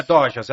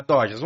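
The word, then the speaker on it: Dojs: É Doujas que fala? Como que pronuncia? Eu não sei. D-O-J-S. É, ah, então é do. É, Dojas. É Dojas, é Dojas.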